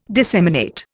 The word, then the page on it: disseminate: Indian